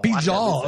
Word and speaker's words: Pijal: Yeah, that's not Spanish. It's Pijal. – It's Pijal? – Pijal. – Pijal. All right. Thank you for affirming that it's not Spanish. – Well, Spanish is the only time you would put a silent J.